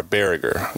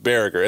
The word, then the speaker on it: Baragar: B-A-R-A-G-A-R, Berger. That's so we've got it on record right here on the Funville 9. That's how the name is pronounced. Anybody's ever doing some research for a start down the road, they pull up this podcast, it's Caleb Berger.